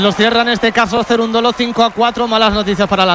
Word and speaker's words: Cerúndolo: Este juego, aunque lo haya ganado Cerúndolo, que lleva acento en la U y tal,